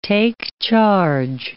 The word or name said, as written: take-charge